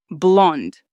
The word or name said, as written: blond